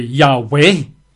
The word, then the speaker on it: Yahweh: Some don't like that because they say the letter J didn't exist. Well, no, it didn't exist, but the pronunciation J certainly existed. So Jehovah, I think, is quite accurate. Some say, nah, it should be Yehovah. Some say it should be Yahweh. Some actually do say it's Yahoo.